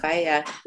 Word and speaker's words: Whaea: And speaking of, your production company is titled the Maori word Faya. – Faya. Yeah. – It's spelled Whaea for those who aren't familiar with the way that Maori language is written and spoken.